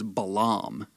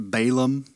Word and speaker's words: Balaam: and his name is Balaam. Balaam. I've heard it pronounced Balaam. You know, either way, Balaam.